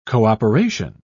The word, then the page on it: co-operation: British English